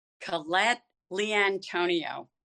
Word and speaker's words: Collette Liantonio: Collette Liantonio.